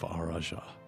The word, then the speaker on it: Araj: You all know where to find the Tower of Araja. – Araja. – I don't know why I have so much trouble pronouncing this name. It's A-R-A-J. Araj.